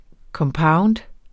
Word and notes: compound: kʌmˈpɑwnd; ˈkʌmˌpɑwnd

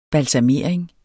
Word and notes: balsamering: balsaˈmeˀɐ̯eŋ; balsɑ-

